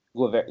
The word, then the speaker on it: Guevara: Guevara. Is that how you say it? Guevara, yeah, Guevara. Yeah, Guevara.